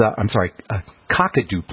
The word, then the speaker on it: Kakadu: Something called Kaduku Plum. All right? Now, Kaduku Plum is, Kakadu Plum is how they say it, supposedly a very rich source of vitamin C.